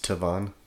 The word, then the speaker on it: Tavon: It's pronounced Tavon.